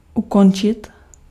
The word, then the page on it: arrêter: France (Paris); [a.ʁe.te]; Europe; /a.ʁe.te/; Quebec; /a.ʁɛː.te/; /a.ʁɛ.te/